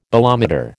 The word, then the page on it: bolometer: American English